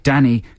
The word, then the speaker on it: Dani: That's the first single from the Red Hot Chili Peppers' ninth album, Stadium Arcadium, and it's called Danny California, spelt D-A-N-I. – That's Danny, I would say. – Would you pronounce it Danny? – I think we're meant to pronounce it Danny, but that's just bad spelling. – Yeah. – Need a double N in there to stop it going all...